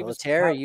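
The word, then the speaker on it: Pilittere: Pilateri, you got it. Good pronunciation. There you go.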